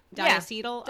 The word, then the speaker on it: diacetyl: So nicotine, which we're going to talk about, ultra-fine particles that can be inhaled deeply into the lungs, flavoring such as diacetyl. Am I saying that right? I've only ever read it. – Yeah. – Diacetyl? – Yeah. Diacetyl, yep.